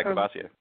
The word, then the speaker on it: Courvoisier: They were all bald, and and then the host was also a black bald guy, and they gave me Kavas Courvoisier, or is that how you say that? – Yeah, Courvoisier. – Cava- what? – Uh, Courvoisier. You, you you said it right.